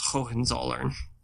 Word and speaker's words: Hohenzollern: I would just like to point out that I correctly pronounced Hohenzollern.